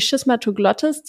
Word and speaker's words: Schismatoglottis: Denn die Schismatoglottis, wird der Name so ausgesprochen? Ja, doch. Ja. Schismatoglottis, genau.